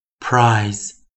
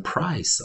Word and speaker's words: prize: P 二的时候，我们念 pr pr pr prize prize prize s s s s size size size 啊，注意啊，还是一样啊，所以 i z e 我们念什么？eyes eyes eyes，最后一遍，prize prize prize size size size。那当然，同学们有的人要注意啊，你不要念成 price 哦，price 是 p r i c e 哦。